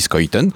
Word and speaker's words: Schuiten: François Scoyton, ça s'écrit Schuiten, mais depuis que j'ai entendu prononcer par lui, je sais que ça dit Scoyton en, flamand.